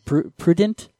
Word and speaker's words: prurient: Appeals to prude. What am I saying? I'm saying this wrong again. Prurient. – No D.